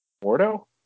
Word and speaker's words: Mordo: Can you say it for me? You know who I'm talking about. – Mordo? – There we go.